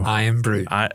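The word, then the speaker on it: Irn-Bru: You gave me, before the holidays, you gave me a nice bottle of, I hope I pronounce it right, Iron Brew. – Iron Brew.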